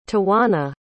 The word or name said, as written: tawanna